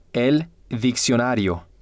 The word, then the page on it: el diccionario: male